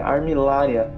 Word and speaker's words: Armillaria: E o nome científico dele, na verdade, ele é do gênero é, Armillaria. É assim que pronuncia, né, Renan? É, isso aí.